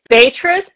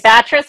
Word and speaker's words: Batrus: It's Batrus, but down south they say Batrus, so either works.